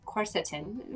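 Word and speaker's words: quercetin: And you had mentioned corset. Let me just say it right. Corsetin. Am I getting it? Am I even closer? – Yeah, corsetin. You mentioned... – corsetin, yes.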